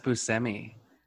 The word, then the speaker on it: Buscemi: I thought it was Buscemi. – No, he says Buscemi.